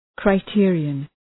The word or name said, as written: criterion